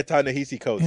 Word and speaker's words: Ta-Nehisi Coates: Ta-Nehisi Coates. – Yeah, Ta-Nehisi Coates, yeah, yes.